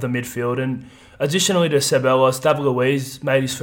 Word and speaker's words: Ceballos: I think we pronounce it Ceballos in the off-season when he came on loan. – It's Ceballos, apparently. – Ceballos. Yeah, he had two cracker assists in his Two cracker assists, bossed the midfield.